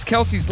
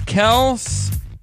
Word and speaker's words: Kelce: Jason and Travis Kelce's last name is not pronounced Kelce. It's Kels with an S sound at the end, not a Z.